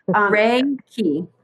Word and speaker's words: Reiki: I don't know Reiki. Why I can't see it pronounce. – Reiki. – Oh, Reiki. Okay.